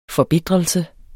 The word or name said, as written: forbitrelse